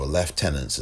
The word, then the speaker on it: lieutenants: We also lost some of the other lieutenants or lieutenants, as it's pronounced in England.